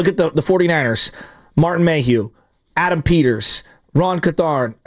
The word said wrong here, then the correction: Carthon: Am I pronouncing it? – Ron Carthon. – Thank you. Sorry, I knew I messed that up.